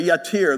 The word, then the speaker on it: yatir: Uh, I'm going to, it's pronounced this way, yatir, though spelled differently.